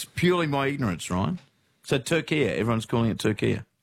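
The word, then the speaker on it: Türkiye: But in yesterday's Socceroos updates, you kept saying Turkey and not – Turkia. – Turkia, what's doing? I'd never heard of Turkia, Ryan, is my honest answer. – Yeah. – Never, ever, ever heard of it pronounced that way.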